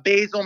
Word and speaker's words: Basile: Yes, you can look at my Facebook handle is my full name, Matthew, M-A-T-T-H-E-W, like you said, A. And then Basil, my last name. It's spelled just like the spice with an E on the end. So B-A-S-I-L-E.